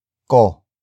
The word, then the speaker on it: ko: Ko. Ko. Ko is spelled as K. O.